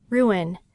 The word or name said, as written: ruin